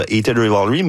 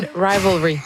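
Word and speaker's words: Heated Rivalry: Et évidemment, on a beaucoup parlé dans la dernière année grâce à la trame sonore de Hated Rivalry, mais... Je l'ai bien prononcé, je pense, c'est la première fois. – Non, Hated Rivalry.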